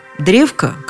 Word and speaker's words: древко: Словари как сговорились. Есть у вас древка, нет древка. Вариант только один – древка. На последний слог ударение ставить не рекомендуется, а когда словарь не рекомендует, уж точно этого делать не стоит.